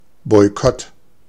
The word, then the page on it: Boykott: /bɔɪˈkɔt/; /ˌbɔɪ̯ˈkɔtə/